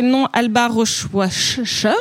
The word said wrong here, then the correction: Rohrwacher: Rohrwacher. Rohrwacher.